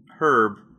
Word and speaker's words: herb: The word herb is pronounced herb in Commonwealth English, but herb is common among North American English speakers and those from other regions where H-dropping occurs.